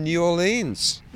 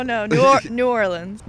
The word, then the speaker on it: New Orleans: just sitting on the beach with her friend is Mary Kate from New Orleans. – Yes, from New Orleans. – I didn't pronounce it right, did I? – No, New Orleans.